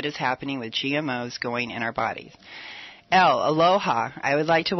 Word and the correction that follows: Elle: Thank you. Ellie. – Ellie, okay. Yeah. I'm sorry. – Well, no, it's spelled like Elle, but yeah, well, thanks for having me. – Okay, Ellie.